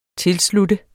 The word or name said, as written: tilslutte